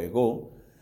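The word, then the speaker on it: ego (ἐγώ): Tiene el acento en la O, ego.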